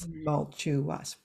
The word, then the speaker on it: tumultuous: Tumultuous. Four syllables.